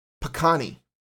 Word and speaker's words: pecan: There is no E in sight in the first syllable of those words. So there's no P. It's pa, pecan. So every one of those pronunciations puts an emphasis on the second syllable.